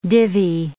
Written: divvy

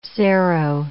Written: cero

